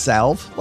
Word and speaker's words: salve: And by the way, isn't it pronounced salve? Like, why do you put an L in a word and not use it? That's just wasteful.